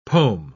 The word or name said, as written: pom